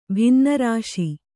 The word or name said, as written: ಭಿನ್ನರಾಶಿ